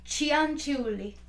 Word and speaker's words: Cianciulli: Um, so it's Leonardo Cianciulli.